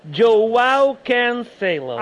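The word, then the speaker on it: João Cancelo: Joao Cancelo.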